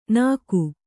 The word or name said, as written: ನಗ